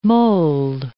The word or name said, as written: mould